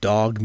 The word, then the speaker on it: dawg: He combined bluegrass and folk and jazz and something he called dog music. D-A-W-G is how you spell it.